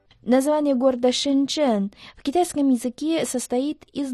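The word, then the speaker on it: Шэньчжэнь: Первый иероглиф «шэн» произносится в первом доне, в переводе на русский «глубокие». Глубоко. Второй иероглиф «чэн» – это четвертый тон, в переводе «ганава». – «Шэньчжэн» можно дословно перевести как «глубокая канава».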